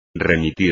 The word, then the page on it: remitir: espagnol